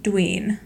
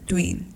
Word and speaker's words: Doyen: And the last team is Shola, and it's spelled Doyen, but Phil pronounced it Dween. – And he himself referred to himself in the third person later in the show, and he said Dween.